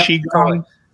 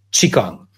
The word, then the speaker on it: Qigong: So anyway, however you spell it in English, it's pronounced Qigong.